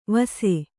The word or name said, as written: ವಸೆ